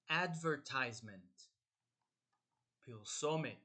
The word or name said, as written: advertisement